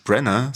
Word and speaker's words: Branagh: Branagh, so spricht man aus.